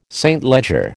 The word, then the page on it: St Leger: American English